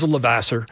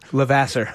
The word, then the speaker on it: Levasseur: one being Raymond Luke Lavoisier. Am I saying that right? – He's from Maine. He just says Levasseur. – Levasseur.